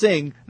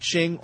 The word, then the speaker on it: Singh: And by the way, my last name is pronounced Singh, not Ching or King, like a lot of people do.